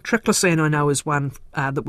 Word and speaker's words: triclosan: Or is that how you pronounce it? – I say triclosan, okay, but I don't actually know what's correct. – No, yours will be correct.